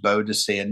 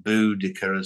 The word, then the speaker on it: Boadicea: It's pronounced Boadicea, not Boudica, as some people like to say.